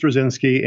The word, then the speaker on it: Straczynski: Is it Straczynski? – Yeah, it is Straczynski.